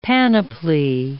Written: panoply

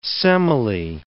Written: Semele

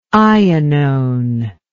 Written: ionone

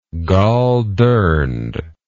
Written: goldurned

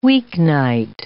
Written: weeknight

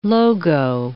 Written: logo

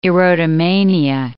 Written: erotomania

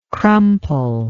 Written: crumply

